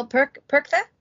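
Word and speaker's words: Perchta: Yes, that brings us to Frau, I'm gonna mispronounce this, my German is terrible, Frau Perkta. – Close enough.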